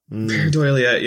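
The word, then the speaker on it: pareidolia: Paradoelia, yes.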